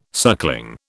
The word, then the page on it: suckling: British English